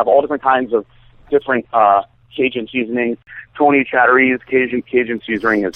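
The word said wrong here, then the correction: Chachere's: Tony Saturies. – Yes, yes, sorry, I pronounced it wrong.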